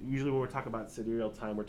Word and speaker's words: sidereal: I think it's pronounced sidereal. That's how I've always heard it.